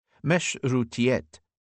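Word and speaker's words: meşrutiyet: Borrowed from the neighbouring Ottoman Empire's first constitutional regime of 1876, which was aborted in 1878 by Sultan Abd al-Hamid, meshrutiyet, as it pronounced in Ottoman Turkish, represented for the Iranians more than just the new conditional system.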